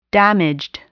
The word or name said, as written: damaged